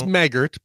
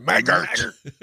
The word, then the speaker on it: Maggart: Again, it's Maggart, you know, because he might listen and might send us an email and say it's pronounced Maggart. – Maggart, you darn Maggart. – Yeah. Looks like Maggot to me, but whatever.